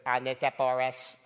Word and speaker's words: Onesiphorus: Little computer lady goes, Onesiphorus.